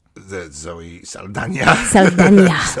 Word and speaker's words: Zoe Saldana: Zoe Saldana. – That's how you really say her name.